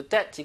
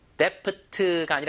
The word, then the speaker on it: debt: Avoid going into debt. 이건 피하다라는 얘기죠. ing 붙여서 go into debt이 뭐예요? 빚을 지는 상태, 즉 대출을 받는 겁니다. D 발음은 거의 무음이에요. Debt가 아니라 그냥 debt. B 없는 것처럼 발음하세요.